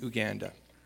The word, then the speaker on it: Uganda: Shannon's very good best friends actually is working at a Bible camp in Uganda. And yes, it's Uganda, just like it's Tanzania. So our Canadian pronunciations of most African countries is not exactly the way that they would pronounce it.